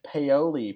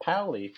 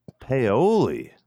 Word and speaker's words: Paoli: It's pronounced Paoli, Pennsylvania, not Pauli. There you go. – Paoli.